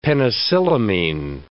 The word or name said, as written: penicillamine